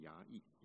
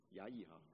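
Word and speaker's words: 雅億: 读成雅意，雅意，雅意，雅意。你看到说在雅意是谁呢？你在五章的四章的十七节里面就告诉我们说，雅意呢，他是一个基尼人西伯的这个妻子哈。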